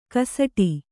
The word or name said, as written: ಗಸಟು